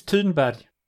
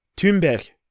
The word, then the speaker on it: Thunberg: For example, now that Greta Thunberg, how do you pronounce her name? – It's actually hard T. Just ignore the H. So it's Thunberg. – Thunberg.